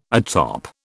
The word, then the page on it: atop: British English